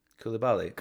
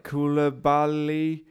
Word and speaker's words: Koulibaly: Kalidou Koulibaly. – Koulibaly.